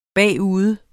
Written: bagude

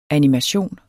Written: animation

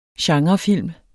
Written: genrefilm

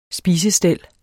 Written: spisestel

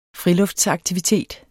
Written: friluftsaktivitet

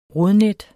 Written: rodnet

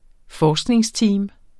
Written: forskningsteam

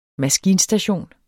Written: maskinstation

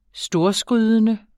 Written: storskrydende

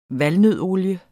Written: valnøddeolie

